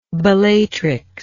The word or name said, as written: Bellatrix